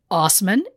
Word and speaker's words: Assman: But um, maybe listeners from Saskatchewan hear the name Ass Man and think of Dave Osman. It's pronounced Osman, but it is spelled Ass Man.